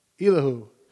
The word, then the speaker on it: Elihu: How you say his name? Elihu. E-L-I-H-U.